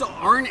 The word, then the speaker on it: Arn: That's Arn Anderson. – Yes. – Arn's still alive. – No, Arn's alive. – He actually pronounced Arn right that time.